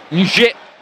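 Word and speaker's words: Njie: Nie, questa è la pronuncia esatta del numero 92 del Torino, classe 2005, ventenne, uno dei talenti nella rosa di Baroni.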